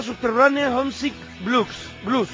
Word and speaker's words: Subterranean Homesick Blues: Dylan está Subterranean Homesick Blues, ¿no? ¿Lo he dicho bien, Carlos? – Lo has dicho del carajo, lo has dicho muy bien, pero, bueno.